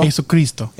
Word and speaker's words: Jesucristo: You don't pronounce that last S? – No, it's Jesucristo. – Jesucristo.